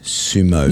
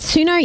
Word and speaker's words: Suno: No, but I think it's pronounced Sumo. – Yeah, I thought you would say that. Suno is an AI music generator, S-U-N-O,